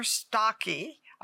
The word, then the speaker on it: Stache: And today we have a special guest, Ginger Stache.